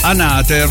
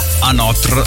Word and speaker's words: Anotr: Allora, volevo subito scusarmi con chi mi ha tirato le orecchie, perché ho detto Anater featuring Ultra e invece alcuni lo pronunciano Anotr cioè fa sempre parte di quei nomi che noi non riusciremo mai a capire.